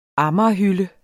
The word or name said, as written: amagerhylde